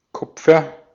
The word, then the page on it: Kupfer: /ˈkʊpfɐ/